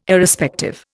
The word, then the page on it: Irrespective: Indian